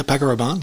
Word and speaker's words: Paco Rabanne: Paco Rabanne, I believe it is. – That's how you say it. Yeah, Paco Rabanne.